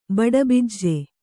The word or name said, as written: ಬಡಬಿಜ್ಜೆ